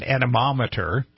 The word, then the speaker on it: anemometer: So these are ways to, if you don't happen to have an anemometer, did I pronounce that correctly? – Correct, yes.